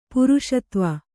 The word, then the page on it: ಪುರುಷತ್ವ: kannada